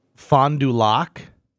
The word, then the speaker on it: Fond du Lac: Fond it's du Lac. Fond and then du Lac. Fond du Lac. – F-O-N-D. Mm-hmm. Small D U. – Yep. – Hence the do. And then Lac. Capital L A C.